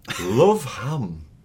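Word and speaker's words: Løv-Ham: Love Ham. – Fib. – True! – No way. – It's another Norwegian one. It looks like L-O-V, but it's pronounced love. So love it's ham. Love Ham.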